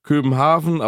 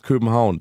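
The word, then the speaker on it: København: Dieses A-V-N, ja. – Weiß nicht warum, Gott weiß wieso, wird aber Au ausgesprochen. Also ist es geschrieben... Köpenhaven, aber ausgesprochen wird es Köpenhauen.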